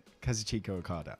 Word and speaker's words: Kazuchika Okada: Kazuchika Okada.